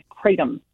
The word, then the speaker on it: kratom: It's a synthetic concentrated byproduct of kratom. And I want you to remember that word. It's spelled K-R-A-T-O-M.